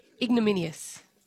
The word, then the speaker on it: ignominious: Ignominious. Is that right? – No. – Not ignominious. – What, how do you say it? – Ignominious. – Ignominious. – There's no L. – Minious. Yeah, remove the L.